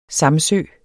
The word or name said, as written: samsø